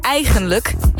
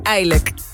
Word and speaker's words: eigenlijk: het woord eigenlijk heeft 3 lettergrepen, hè? Niet eilijk.